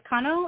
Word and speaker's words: Cano: It's Cano. It's a Hispanic name, but I also take Kano, like volcano. I grew up pronouncing it that way, so I don't say it right either.